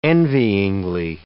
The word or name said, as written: envyingly